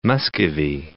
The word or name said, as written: Muscovy